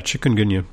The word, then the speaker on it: chikungunya: While I was there, I kept hearing about this chikungunya. – Yeah, chikungunya.